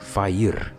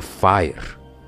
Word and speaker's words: fire: Dieda, Emai, é Fair. É Fire. A pronúncia certa é Fire.